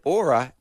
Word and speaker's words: Oura: Written, yeah, but it's spelled O-U-R-A, but the wonderful people who've written the ad have said Aura as in flora, just in case I don't know how to pronounce it.